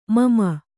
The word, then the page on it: ಮಮ: mama